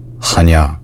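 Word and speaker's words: Chania: Kania, Kainia, ja, jeg har litt trøbbel med uttalen der. Er det mulig å få litt hjelp? Hvordan uttales? Er det Kania som rett? – This city is called Hanya.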